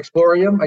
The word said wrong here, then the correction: Exploratorium: I guess it is. How do you say that? Exploratorium? – Yeah, just slow down a little bit. Exploratorium.